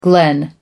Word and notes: glen: American English

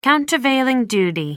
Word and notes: countervailing duty: American English

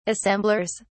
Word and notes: assemblers: British English

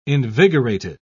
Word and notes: invigorated: British English

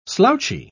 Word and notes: slouchy: British English

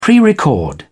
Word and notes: prerecord: British English

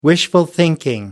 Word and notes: wishful thinking: American English

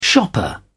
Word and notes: shopper: British English

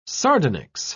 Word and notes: sardonyx: British English

